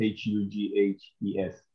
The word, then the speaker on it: Hughes: S O K L E V A H U G H E S.